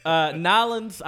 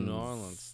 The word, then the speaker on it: New Orleans: Nolens, I've heard a lot too. That one apparently is also not legit. – Really? – The correct way to say it is New Orleans. – Oh, New Orleans.